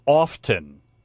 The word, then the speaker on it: often: Often rhymes with soften. Do not say often. – The T is silent. – The T is silent. So it's often, not often.